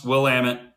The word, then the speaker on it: Willamette: So if you ever want to know how to pronounce Willamette, it's Willamette.